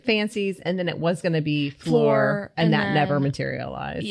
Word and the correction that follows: Fleur: I'm like floor, like as if I'm like saying that so Southern. – As in like sweep the floor. It's like fleur.